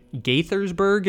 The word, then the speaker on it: Gaithersburg: Gaithersburg is how I believe it's pronounced,